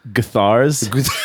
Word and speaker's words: Gutherz: I think it's Gutherz. Pronounced Guthars. like Johnny Guthar.